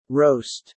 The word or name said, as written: roast